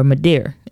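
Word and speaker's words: Madea: It would be Medea or Madeir, as it's, you know, southernly pronounced.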